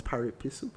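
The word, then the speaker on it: pari passu: They cannot remove the value because I own the shares and all shares are paripasu. If there was a lawyer in the room that would help me, it would be so great. How do you pronounce paripasu? – Paripasu.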